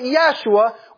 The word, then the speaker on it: Yeshua: Some people pronounce it Yeshua.